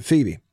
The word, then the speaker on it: Pheba: Number two, it's not spelled in such a way as you would think you'd pronounce it, Phoebe. It's actually sort of spelled Phoebe. P-H-E-B-A. But you pronounce it Phoebe.